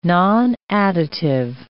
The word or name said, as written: nonadditive